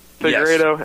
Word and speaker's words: Figueiredo: If I'm saying that right. – The Figueiredo yes.